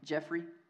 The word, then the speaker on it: Geoffrey: Hey, if I've never had the pleasure of meeting you, my name is Jeffrey. I'm the pastor here. My name is spelled G-E-O-F-F-R-E-Y.